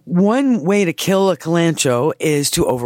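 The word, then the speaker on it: Kalanchoe: Okay, so just to be clear for our other listeners, I would call it calancho. Some people call it calancoe, depending on how we pronounce our Latin.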